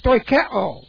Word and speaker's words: stoicheo: Now, the Greek word is stoicheo. S-T-O-I-C-H-E long O. Stoicheo.